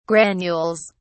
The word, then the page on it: granules: British English